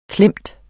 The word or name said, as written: klemt